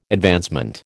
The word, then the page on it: advancement: American English